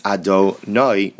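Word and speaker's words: Adonai (אדני): So the Mechaber seems to say that even though we're going to pronounce the name of God as Adonai, we're not going to pronounce it as Yud Hei and then Vav Hei. Nevertheless,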